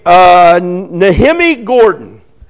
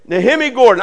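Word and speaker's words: Nehemia Gordon: And one of the best ways, I was with a guy named Nehemi Gordon. That's the way you say his name. Nehemi Gordon.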